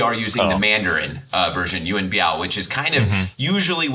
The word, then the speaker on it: Yuen Biao: But obviously in Cantonese, so when you have people talking about him in Cantonese, you know, for example, our friend Sifu Alex at the Kung Fu Genius podcast, they're going to use the Cantonese pronunciation of Yuen Biu.